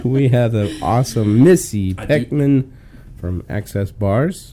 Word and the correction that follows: Peckham: Yeah, you say it like Peckham, like bend it like Peckham. – Peckham. Oh, so yeah. Sorry. – Peckham instead of Beckham.